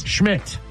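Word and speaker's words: Szmyt: Is it Schmidt? – Schmidt, yes. It's spelled S-Z-Y.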